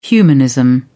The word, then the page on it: humanism: British English